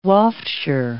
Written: wafture

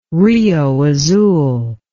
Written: Rio Azul

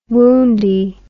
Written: woundy

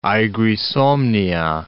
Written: aegri somnia

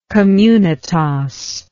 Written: communitas

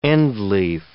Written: endleaf

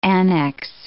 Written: annexe

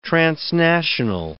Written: transnational

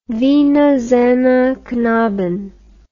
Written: Wiener Sängerknaben

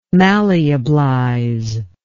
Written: malleablize

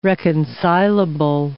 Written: reconcilable

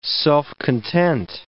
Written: self-content

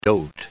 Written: dote

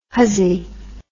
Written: huzzy

